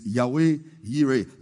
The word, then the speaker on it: Yahweh Yireh: Now, but in the real pronunciation in the Hebrew is Yahweh Yireh.